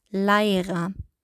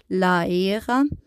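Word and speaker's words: læra: Bei Leira, wir als Deutsche, wir sehen da ein A und ein E und wir würden wahrscheinlich irgendwie lesen wie Leira. Aber im Isländischen wird es als AI ausgesprochen und damit ihr wisst, was Leira heißt, also Leira heißt lernen.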